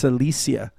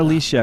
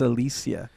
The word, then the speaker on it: Alicia: It's Alicia. Her name is not Alicia. Everybody's been saying it wrong all these years. It's Alicia.